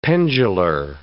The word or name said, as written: pendular